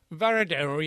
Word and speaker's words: Varadero: Hey, one thing is the opposite of Trinidad and the colonial charm is Varadero. Is that how you pronounce that? – Varadero, yes.